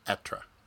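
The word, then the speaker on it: Être: Anyway, it's H E with a circumflex over the E, which I don't remember what that's called in French. T R E. So it's pronounced Etre. – Okay. – Because the H is silent and it's eh, eh. It's a short E.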